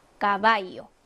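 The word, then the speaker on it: caballo: 까바이요 해서 쌍기역 발음이 나고 말이란 뜻이죠.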